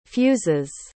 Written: fuses

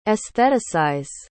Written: aestheticize

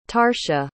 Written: tarsha

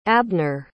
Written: abner

